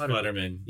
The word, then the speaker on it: Futterman: Is it pronounced Futterman? – No, it's Futterman.